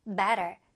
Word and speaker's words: better: 자, better라고 안 들렸고 가운데 트 발음이 얼로 약화됩니다. 많이 약화되죠? 그래서 better.